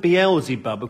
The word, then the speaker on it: Beelzebub: I would pronounce it Beelzebub.